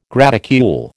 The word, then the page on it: graticule: American English